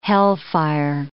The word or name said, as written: hellfire